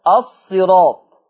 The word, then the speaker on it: الصراط: خلي بالكم، شوف أنا أقولها إزاي، الصراط. طب ليه ما أقولش الصراط؟ الصراط حتى النطق بتاعها مش جاي، الصاد مكسورة الصي والراء، يبقى أقول الصراء مفروض كده. قال لك بس وراها ألف وطاء، الصراط طاء، عشان كده منع ترقيق الراء.